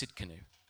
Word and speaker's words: Tsidkenu: The T is silent, so it's Tsidkenu.